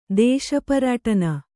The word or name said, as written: ದೇಶಪರ್ಯಟನೆ